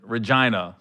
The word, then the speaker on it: Regina: We were, well, the first we ever did was in Regina, Saskatchewan.